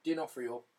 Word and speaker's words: D'Onofrio: Vincent D'Onofrio. Can never pronounce it. – D'Onofrio.